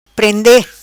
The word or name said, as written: Prende